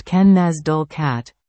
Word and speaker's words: Lenasdulcat: Lenasdulcat, pronounced Ken-Naz-Dul-Cat,